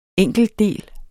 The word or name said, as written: enkeltdel